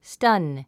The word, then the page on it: stun: stʌ́n; スタン